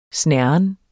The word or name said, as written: snerren